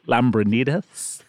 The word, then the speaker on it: Lambrinidis: Lambranitas. – Can I say it like you? Lambranitas. – That's the proper Greek pronunciation.